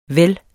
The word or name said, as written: vel